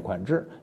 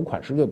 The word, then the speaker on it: 款识: 比如我们讲过的“ 款制” 啊，我们有时读“ 款识”，就是认识的“ 识”。款制呢是指款式平面刻下去的为款，凸出来的为实啊。所以这两个字捏在一起，“ 款制” 啊，准确发音念“ 款制”，你读“ 款识” 就读“ 款识” 了。